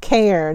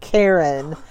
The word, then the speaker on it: cairn: He mispronounced Cairn. He pronounced it Karen.